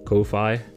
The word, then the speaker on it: Ko-fi: I don't know how to pronounce it. – Ko-fi. – Ko-fi? – Exactly. It's confusing as fuck. Coffee. I feel like it's derived from coffee, but they spelled it so weird.